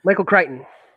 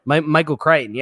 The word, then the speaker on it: Michael Crichton: I mean, anything with Michael Crichton, that's how you pronounce it? – Michael Crichton. – Michael Crichton, yeah.